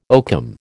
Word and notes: oakum: American English

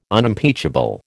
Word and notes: Unimpeachable: American English

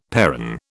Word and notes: perron: British English

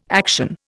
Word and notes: action: Indian

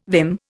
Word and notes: vim: Indian

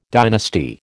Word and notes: dynasty: American English

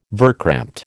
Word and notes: verkrampte: American English

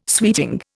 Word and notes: sweeting: Indian

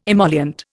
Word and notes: emollient: Indian

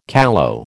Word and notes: callow: American English

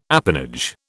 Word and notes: apanage: British English

